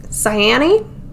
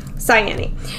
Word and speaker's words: Cyane: So out of all her friends, the one nymph, Cyane, that's what we're going to go with. That's what YouTube told me was going to be the pronunciation here. – Cyane.